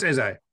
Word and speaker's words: Eze: Now, am I pronouncing it right? Is it easy? – No, it's Eze.